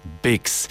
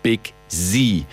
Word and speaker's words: Biggz: Ich habe beim letzten Mal leider, weil ich nicht wusste, wie er richtig ausgesprochen wird, gesagt, sein Name ist Big Z. Er wird geschrieben B-I-G-G-Z und es wird Bix ausgesprochen.